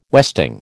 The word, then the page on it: westing: American English